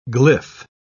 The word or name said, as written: glyph